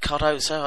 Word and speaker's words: Cardozo: Um, uh, uh, Cardozo, is it? Cardozo, I don't mm. know, is that how you pronounce it? Um, yeah, yeah,